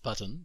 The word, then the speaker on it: button: ボタン Well, it's pronounced button, but yes.